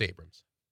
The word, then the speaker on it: Abrahams: Yeah, it's Abrams. – They have it Abrahams. Am I just saying it wrong? Is it pronounced Abrams? – It's pronounced Abrams.